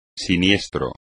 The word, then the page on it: siniestro: espagnol